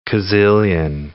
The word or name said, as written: kazillion